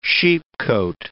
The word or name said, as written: sheepcote